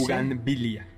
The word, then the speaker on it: bugambilia: Bugambilia. – Pero en México impera el utilizar la MB, ¿no? Bugambilia.